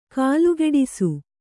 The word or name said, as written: ಕಾಲ್ಗಿಡಿಸು